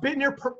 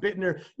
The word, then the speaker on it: Bittner: Is it Bittner? – It's pronounced Bittner. It's spelled Bittner, pronounced Bittner.